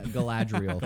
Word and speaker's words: Galadriel: But yeah, it's Galadriel. – Galadriel. – But looping back to correct you again, Galadriel.